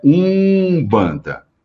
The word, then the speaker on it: umbanda: Só que a pronúncia, quando a palavra era escrita com M ou com N, eu vou pronunciar com U sempre. Então é umbanda. Então a pronúncia é essa.